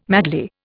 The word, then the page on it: madly: Indian